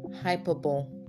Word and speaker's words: hyperbole: Hyperbole. That's what a lot of Nigerians say. But the real pronunciation is hyperbole. All right, the E sound at the end is always articulated because it's a French word.